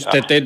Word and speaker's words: Τέτε: Πάντως Τέτε το λέει το παιδί, έτσι. Μπορεί όντως αυτό ο γιος του Μίχου μας είχε πει, γιατί τον ήξερε πολύ καλά, ότι προφέρεται Τέτε.